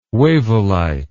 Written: wavellite

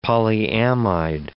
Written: polyamide

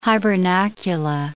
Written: hibernacula

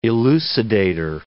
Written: elucidator

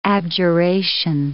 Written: abjuration

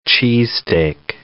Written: cheesesteak

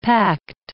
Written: pact

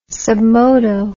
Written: sub modo

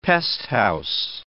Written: pesthouse

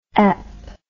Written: ep

